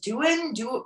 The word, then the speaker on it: Duin: And she had written a book, Julia, I think her pronounced name is Duin, D-U-I-N.